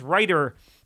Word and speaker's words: Rajter: It's spelled Rajter, but I think it's pronounced Reiter.